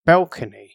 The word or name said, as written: balcony